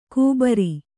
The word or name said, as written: ಕೂಬರಿ